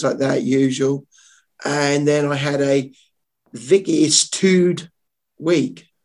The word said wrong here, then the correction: vicissitude: Vicissitude. So, Jem, I, as I've pronounced that totally wrong, how do you pronounce it? Vicissitude.